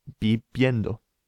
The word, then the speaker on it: viviendo: It's pronounced, the V is pronounced like a kind of B sound. So, bib, and we add the ending yendo, viviendo. – Viviendo. – Viviendo. Yeah, it's quite a funny sound, that, the V sound. It's kind of halfway between our English B and V.